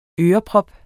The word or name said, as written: øreprop